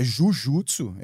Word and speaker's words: jujutsu: Então é jujutsu, é o termo correto, né? Aí por uma questão de pronúncia,